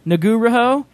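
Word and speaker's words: Ngauruhoe: Mount Nagaroho? – Naguro. – Naguro? – Naguroho. – Naguroho was used as a stand-in for the fictional Mount Doom.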